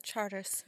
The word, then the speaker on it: Chartres: I always forget how to say this. Chartres Street? – Chartres, yeah. – Yeah. – It's like Chartres.